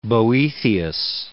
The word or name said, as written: Boethius